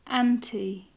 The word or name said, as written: anti